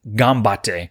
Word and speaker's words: ganbatte: All right, so gambatte means do your best or get 'em or knock 'em dead, all rolled into one, and it's spelled G-A-N-B-A-T-T-E, gambatte. Now, it's a word, of course, that's used to encourage others or to encourage yourself to do your best.